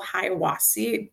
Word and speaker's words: Hiawassee: So we stayed, I believe the town is called Hiawassee.